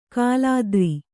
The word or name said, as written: ಕುಲಾದ್ರಿ